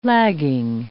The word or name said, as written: lagging